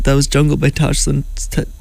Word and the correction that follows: Tash Sultana: Tash Sultana.